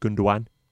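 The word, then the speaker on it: Gündoğan: And you know, when you've got a deep lying playmaker like I can never pronounce it correctly, but I think it's like Gundogan. – Yep.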